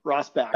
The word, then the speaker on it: Rosbach: I can tell that you've spent time in Germany by the way you pronounced my last name the first time, Rosbach.